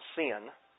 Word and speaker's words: Sin: we might pronounce Sin. But let's not confuse the two, because the biblical location is pronounced Sin, like in S-E-E-N.